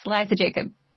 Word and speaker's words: Liza Jacob: Liza Jacob.